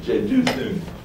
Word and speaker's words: Jeduthun: That's Thun, actually. Thun. Jeduthun.